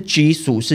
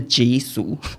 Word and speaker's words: Jisoo: 他说不是居俗是 G 俗，我想说好难。